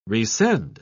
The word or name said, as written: resend